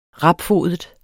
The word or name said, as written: rapfodet